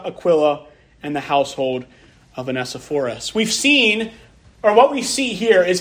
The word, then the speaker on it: Onesiphorus: In verse 19, again, it says, "Greet Prisca and Aquila and the household of Onesiphorus." I looked up how to pronounce that word, and that's how they said to pronounce it. Onesiphorus.